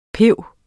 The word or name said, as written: piber